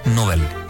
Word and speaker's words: Nobel: En realidad hay que decir Nobel, porque no lleva tilde.